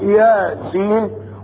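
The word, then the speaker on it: يس: دي حروف مقطعة زي الف لام ميم صاد الف لام راء، جه بعدها خطاب للنبي ومعها هذه حروف مقطعة برضو ياسين، وعمرها ما تتنطق كده. هات قارئ قرآن يقرأ ياسين والقرآن الحكيم، هتضربوه بالنعال بعد الصلاة. لابد ان تنطق على انها حرفان مقطعان، يا سين طاء هاء.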